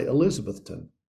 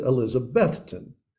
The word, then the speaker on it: Elizabethton: And we can tell the foreigners because they say Elizabethton. If you know what Elizabethton is, you pronounce it Elizabethton. The emphasis is on the middle syllable.